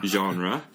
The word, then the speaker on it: genre: 근데 존 덴버 불렀던 컨트리 음악 밑에 속하는 장르는 genre 미국식 발음으로 하면 genre 민요, 폭상 하고 더 비슷하죠.